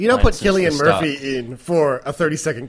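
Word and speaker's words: Cillian: I actually saw him interviewed, and he did confirm it was Killian. It's Celtic.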